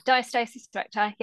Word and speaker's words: diastasis recti: Um, you also, um, I've seen you do a lot of work with, forgive me if I pronounce it wrong, diastasis recti. – Yeah, diastasis recti.